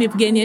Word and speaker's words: Eugenia: Yes, I'm Eugenia Shamanov.